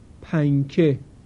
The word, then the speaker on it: پنکه: در کلمه پنکه معمولاً سر زبان به لسه برخورد نمی کنه، بلکه صدای نون در پنکه به صورت غنه و با امتداد بیشتر از بینی خارج میشه.